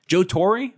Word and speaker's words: Torre: Joe Torre? I think that's the way it's pronounced. Joe Torre.